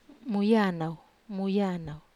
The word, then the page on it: muyanau: wamesa